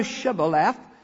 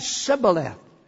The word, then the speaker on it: Shibboleth: Then said they unto him, say now Shibboleth. And he said Sibboleth, for he could not free him to pronounce it right.